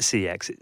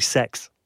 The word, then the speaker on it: CeX: Actually, you don't pronounce it CEX. It's actually Sex.